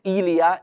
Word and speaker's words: Elia: It's pronounced Elia in their Hebrew language.